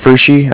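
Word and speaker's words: Pferschy: It's Fushi.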